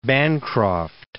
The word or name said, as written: Bancroft